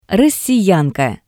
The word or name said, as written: Rossiyanka